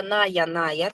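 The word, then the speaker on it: Naja naja: Значит, индийская кобра, ная-ная, так она и пишется, наджа-наджа.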